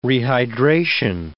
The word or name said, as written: rehydration